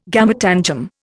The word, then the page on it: gametangium: Indian